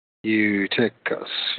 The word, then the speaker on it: Eutychus: I guess I pronounce it. It's, U, it's Eutychus. Eutychus.